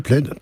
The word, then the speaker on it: Plaid: Alors, les deux derniers sont partis ensuite créer le projet Plaid. Je sais pas comment, si ça se prononce comme ça. – Si, Plaid, tout à fait.